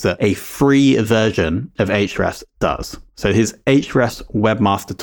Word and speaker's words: Ahrefs: Before we jump into that, though, of course, we have to give a shout out to our insanely great sponsor, Ahrefs, or Ahrefs as the name should be pronounced.